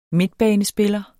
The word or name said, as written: midtbanespiller